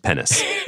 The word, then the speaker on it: PEN15: I believe it's pronounced penis.